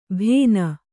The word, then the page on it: ಭೇನ: Kannada